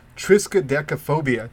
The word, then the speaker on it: Triskaidekaphobia: So, like, this was the first card I said when the idea of building a spooky deck was, and it's called Triskaidekaphobia. Did I say that right? – Yeah. – Yeah. – All right. So, Triskaidekaphobia is an enchantment, another enchantment.